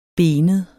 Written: benet

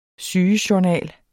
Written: sygejournal

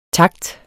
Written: takts

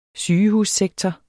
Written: sygehussektor

